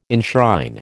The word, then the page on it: enshrine: American English